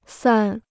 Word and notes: 散: sàn

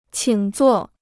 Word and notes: 请坐: qǐng zuò

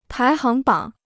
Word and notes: 排行榜: pái háng bǎng

